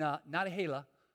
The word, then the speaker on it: Nantahala: Nantahala, is that how you say it? And Nantahala? Yeah, that place, okay, in North Carolina.